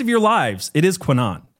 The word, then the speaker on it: QAnon: So the first thing I have to say about QAnon is that you are pronouncing it wrong. – I know. Spencer told me. – Okay. Our friend Spencer Clavin, who's done a show with us, informed us that it is pronounced QAnon. And that is how we are going to pronounce it, if I can remember.